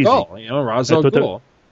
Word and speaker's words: Ra's al Ghul: It was Ra's al Ghul. I wouldn't put an H there looking at it because, you know, it's R-A-S.